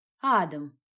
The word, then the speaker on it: Адам: Адам! С ударением на первый слог.